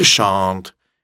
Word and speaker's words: shan't: То же самое shall not или shan't. Это произносится чуть-чуть иначе. Shan't, shan't, мягкое ш.